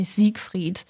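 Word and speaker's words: Seigfried: In German, we would say Siegfried.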